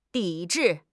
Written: 抵制

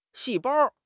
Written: 细胞

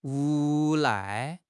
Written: 无赖

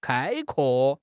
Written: 开阔

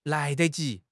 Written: lai2 de5 ji2

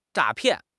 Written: 诈骗

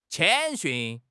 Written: 谦逊

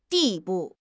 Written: 地步

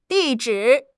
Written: di4 zhi3

